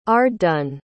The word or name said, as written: arddun